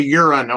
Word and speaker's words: Huron: Just don't, say Huron, Ohio. – Yes. The H is silent.